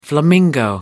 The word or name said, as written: flamingo